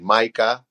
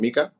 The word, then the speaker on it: MiCA: El otro día nos reíamos porque la gente discute sobre la pronunciación de si Maica y Mica. Bueno, ya la comisión ha dicho que es Mica, o sea, que cuando alguien diga Maica, pues que sepa que se muere un gatito, que hay que decir Mica.